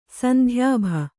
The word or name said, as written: ಸಂಧ್ಯಾಭ